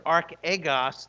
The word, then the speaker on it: archegos: Because this word archegos, think about it. That's how you pronounce it in Greek, but it's actually the word arch-ego.